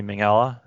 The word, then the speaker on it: Minghella: It's a 2006 film, written and directed by Anthony Minghella.